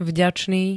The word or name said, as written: vďačný